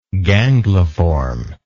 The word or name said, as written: gangliform